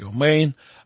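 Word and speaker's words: омейн (אמן): Аминь, по-еврейски «омейн», означает «истина».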